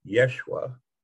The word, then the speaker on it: Yeshua: During the time that he actually walked on the surface of the earth, his name was actually pronounced much more like Yeshua, which I will refer to him as in this conversation.